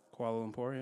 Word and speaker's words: Kuala Lumpur: I don't know how to say this. Kuala Lumpur? – Kuala Lumpur, yeah. – Kuala Lumpur? Okay. Kind of said it right.